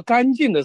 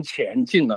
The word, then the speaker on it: 干净: 他在念到那个" 干净" 的时候，他就念成" 前进" 了，因为那个" 干" 和乾坤的" 乾" 哈。